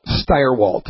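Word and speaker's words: Stirewalt: Yeah, so Jillian was there, and Chris Stalwart, I always say his name wrong, but Steyerwalt, the guy that does all the political analysis, pretty good.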